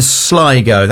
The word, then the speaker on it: Sligo: Now, they formed in Sligo. That is how you pronounce it, isn't it? It's not Sligo, Sligo.